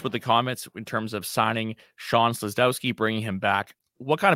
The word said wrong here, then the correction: Szydlowski: Boy, we need to work on that pronunciation. Szydlowski. Szydlowski. Shawn That's a hard one.